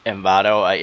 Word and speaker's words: Envato: or how would you say it in English? Envato. – Envato, yeah, I mean, that sounds right to me.